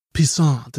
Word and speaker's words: Pissant: I remember in that movie he goes, "Oh, it's spelled Pissant. It's pronounced Pissant. It's French. It's French." – Pissant. – Yeah. So Like croissant, but with piss.